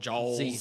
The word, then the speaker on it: Jalz: You have a show, and you have to pronounce it for me because I've only seen it spelled out. It's J-A-L-Z. – Jaws. – Jaws. – Jaws, yeah.